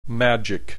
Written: magic

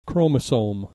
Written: chromosome

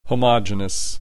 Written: homogeneous

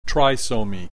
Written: trisomy

